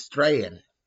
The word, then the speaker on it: Strachan: It's Strahan, like Strahan, but it's spelled Strachan.